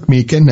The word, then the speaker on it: Meechan: Again, my name is Patrick Meekan. That's spelled M-E-E-C-H-A-N.